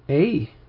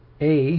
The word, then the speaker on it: Ai: Verse 3, howl, O Heshbon, for Ai, how else can we say it? Ai, we can say it, we can pronounce it. Ai has been despoiled.